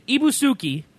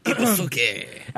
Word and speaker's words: Ibusuki: In Ibusuki, is how that would be pronounced. – Ibusuki.